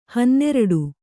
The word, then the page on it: ಹನ್ನೆರಡು: Kannada